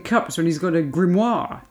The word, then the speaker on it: grimoire: What, do you put the pronunciation on grim or moir? – I think grim, isn't it? – A grimoire. – Grimoire. – Not a grimoire. – Otherwise it sounds like a soiree.